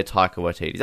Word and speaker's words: Taika Waititi: no, they love their Taika Waititi. I feel like I'm saying his name correctly for once, so I'm proud of myself. – Taika Waititi.